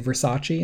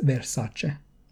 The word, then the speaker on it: Versace: because people always say Versace, and she's like, "Honey, it's Versace."